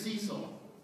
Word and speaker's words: Cecil: I want to talk about, I've been asking all week long, how do you pronounce this lion's name? Is it Cecil or Cecil? It is Cecil.